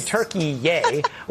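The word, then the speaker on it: Türkiye: Turkey Yay, which for those of us who did not know, apparently that's the new way to talk Turkey or pronounce it.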